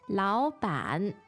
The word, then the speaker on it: 老板: 老板. 老板. – 老板. The tones there are? – Two-third tones.